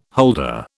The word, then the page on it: holder: British English